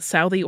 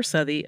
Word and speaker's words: Southey: You can say that Southey or Southey, according to pronunciation guides.